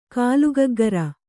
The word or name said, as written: ಗಲ್ಲಗುಗ್ಗುರಿ